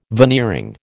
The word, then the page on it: veneering: American English